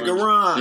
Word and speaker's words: Goran: He said Goran. – Yeah. – That's how you pronounce that shit.